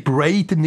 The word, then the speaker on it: Braden: Nein, Braden, nicht Brandon. Braden 100 Mark. – Das Einzige, was er jetzt verstanden hat, dass wir fünfmal seinen Namen falsch gesagt haben. – Braden, also gell, also B-R-A-D-E-N spricht man auch Braden aus.